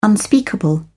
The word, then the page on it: unspeakable: British English